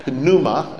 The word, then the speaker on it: pneuma: Or some people call pneuma. It's actually pneuma with a silent P.